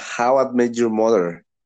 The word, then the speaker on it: How I Met Your Mother: How Do You Say the Mother? – No. – ¿Cómo conocí a su madre? – Ah, how I met your mother.